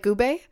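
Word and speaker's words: Goo Hara: It's literally, it's G-O-O, like Gube. – Oh. – Gu. And then last name is H-A-R-A. Hara.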